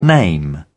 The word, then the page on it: name: British English